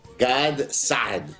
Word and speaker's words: Gad Saad: So that's G-A-D-S-A-A-D. Before the break, Senor Saad was actually coaching me on how to say it with the proper Middle Eastern way. How do I do that, sir? – Gad Saad. – Gad Saad. Kind of. It's there you go.